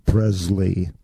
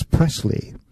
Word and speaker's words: Presley: It offends me when people say Elvis Presley, because honestly, it's Presley. That's the way it was, and that's the way he wanted his name to be pronounced.